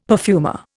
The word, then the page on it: perfumer: Indian